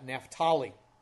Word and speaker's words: Naphtali: Verse 1 then says, "In the former time he brought into contempt the land of Zebulun and the land of Naphtali," or I think some people pronounce that Naphtali.